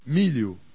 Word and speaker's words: Milho: LH is pronounced like the LI of battalion, and NH is pronounced like the NI of onion. – Milho.